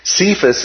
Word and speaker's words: Cephas: Now, Cephas is an anglicized pronunciation. It comes from the Aramaic word kephas, which is, like Peter, means rock or stone.